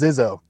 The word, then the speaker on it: Zizzo: No, I think you hit it. It's Zizzo. – Zizzo. I think I was saying Sizzo, but yeah, okay.